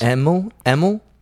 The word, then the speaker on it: Emil: Say his name. E-M-I-L, Emil Brankus. – Emil? – Yeah, Emil. – Emil. – E-M-I-L, Emil Brankus.